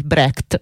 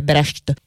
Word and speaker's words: Brecht: che è quello di Bertolt Brecht. Lo chiamiamo così in italiano, non stiamo lì a vedere qual è la sua pronuncia tedesca esatta, che sarebbe Brecht, perché dagli anni 20 in poi,